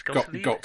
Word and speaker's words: Gottlieb: Gottlieb. – Gottlieb,